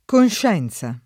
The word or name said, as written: conscienza